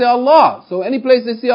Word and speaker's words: Allah: Or in this case, you pronounce it with tafkheem. You say, Allah.